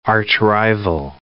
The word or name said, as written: archrival